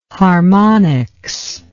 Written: harmonics

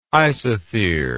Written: isothere

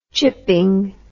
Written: chipping

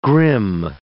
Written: grim

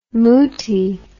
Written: muti

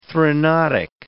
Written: threnodic

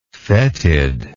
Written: fetidness

